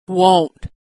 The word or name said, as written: won't